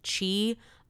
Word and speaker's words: Chi: So my legal name is Qi Un Ko. So that's spelled C-H-I.